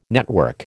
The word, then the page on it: network: American English